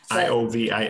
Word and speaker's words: Iovine: Iovine.